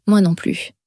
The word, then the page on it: moi non plus: french